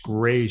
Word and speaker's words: Gray: Welcome to the Mitch Gray Show.